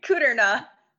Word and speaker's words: Kudrna: Kudrna.